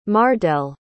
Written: mardell

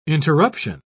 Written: interruption

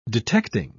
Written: detecting

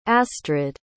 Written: astrid

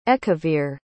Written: Ekaveer

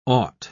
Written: ought

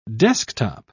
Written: desktop

